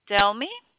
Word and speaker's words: Delmy: Delmy. – Can you spell that? – D-E-L-M-Y.